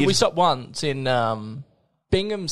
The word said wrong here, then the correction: Brigham: City. – Brigham? – Brigham City, sorry. – Brigham. – Brigham. – Brigham. – I can't pronounce that. – Yeah, it's Brigham.